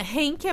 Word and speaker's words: Henk: Hank? Não, é Henk é porque é alemão. E hum. na Alemanha o H se lê com um som de R.